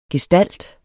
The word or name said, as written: gestalt